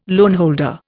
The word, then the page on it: loanholder: Indian